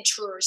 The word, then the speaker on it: Tours: She didn't live in Tours. So T-O-U-R-S, France. Tour France, right? The S is silent as far as I know.